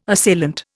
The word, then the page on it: assailant: Indian